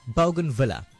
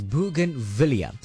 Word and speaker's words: bougainvillea: Now, many people pronounce this particular plant incorrectly. They say bougainvillea. That's incorrect. It's pronounced as bougainvillea.